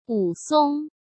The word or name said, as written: Wu Song